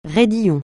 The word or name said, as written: raidillon